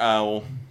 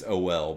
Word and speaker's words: OL: Or owl. Yeah, I don't. I don't think it's owl. I think it's OL, but it's like like Noel. They didn't spell like the what L. rhymes with Noel, and they're yeah, like OL.